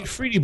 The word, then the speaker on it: efreeti: I've always called it Efreeti. Is it pronounced a different way to you guys? – I've always said Efreet. – Efreet? I've said Efreeti. That's how I've always pronounced it. How you pronounce it however you want.